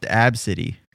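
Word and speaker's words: Abcde: A white mom named her white daughter A-B-C-D-E. A-B-C-D-E. – Yeah, it's pronounced Absidy.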